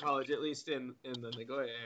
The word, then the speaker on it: Nagoya: And it's pronounced Nagoya.